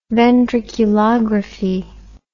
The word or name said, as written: ventriculography